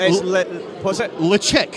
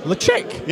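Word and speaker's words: Ledaig: But it's not pronounced Ladaig. It's pronounced... Lechick. – Lechick? – Yeah.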